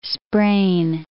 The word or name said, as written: sprain